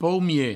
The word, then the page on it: paumier: paumie; provencal